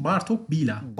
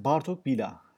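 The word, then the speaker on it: Bartók Béla: Neyse, doğru okunuşu Macarlar soy ismi başa koyduğu için Bartok Bila. – Bartok Bila.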